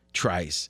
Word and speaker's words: Trice: Okay, sit back and bear with me a second here because I want to talk about the Steelers' six round pick, Corey Trice. And is that the right pronunciation? That's correct. Thank you.